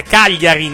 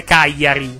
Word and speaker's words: Cagliari: No, è Cagliari, non è Cagliari, scusi. – È Cagliari! – Mi permetta, si pronuncia I, quindi Cagliari, non Cagliari. Cagliari lo dicono a Roma, con tutto il rispetto per i romani.